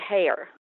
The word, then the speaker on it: Heyer: First of all, just so you know, the Brits pronounce it Heyer. – Heyer, okay. – Yeah, I always pronounced it Heyer, too, and then when I was in England, got firmly corrected.